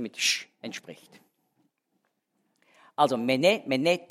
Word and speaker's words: mene: Mene, mene, tekel, ufasin. Ich habe jetzt also auch richtig betont, ja? Also mene ist endbetont. Mene, mene, tekel, die erste Silbe betont.